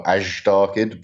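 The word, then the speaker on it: Azhdarchid: So since you have this new pterosaur, it's an Asdarchid, right? Is that how you say it? – Yep. Some people emphasize the zh a bit, so more Asdarchid, but it's fine.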